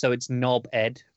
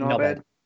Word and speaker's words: knobhead: Most people in England don't pronounce the H. It's knobhead. Like, so it's just like knobhead. Yeah, and just say it like that.